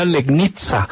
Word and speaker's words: Legnica: Para el Mietz Legnica se escribe, pero dicen que se pronuncia Legnica.